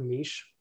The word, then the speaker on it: niche: Was that you really mispronouncing the word niche? – Yes. – Okay. – Yeah. – I appreciate that you pronounce the word niche like it's a type of bread.